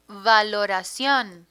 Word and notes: valoracion: Spanish

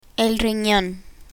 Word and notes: rinon: spanish

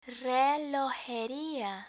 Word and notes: relojeria: Spanish

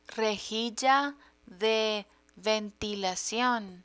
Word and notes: rejilla de ventilacion: Spanish

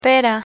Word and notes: pera: Spanish

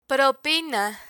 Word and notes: propina: Spanish

